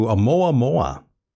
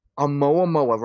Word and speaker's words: Oumuamua: Yes, you're referring to Oumuamua. – Oumuamua, right. I couldn't pronounce the name. It's a Hawaiian name.